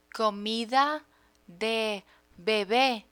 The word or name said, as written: comida de bebe